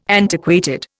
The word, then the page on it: antiquated: Indian